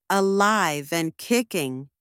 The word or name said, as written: alive and kicking